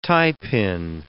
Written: tiepin

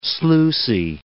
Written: sluicy

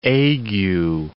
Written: ague

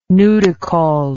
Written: nudicaul

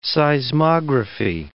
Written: seismography

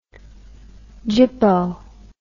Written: gippo

